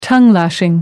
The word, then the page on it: tongue-lashing: American English